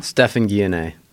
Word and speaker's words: Stephan Guyenet: Stephan Guyenet. – Guyenet. – Yeah. – Guyenet. – It's like okay. DNA. – Like DNA, but with a G.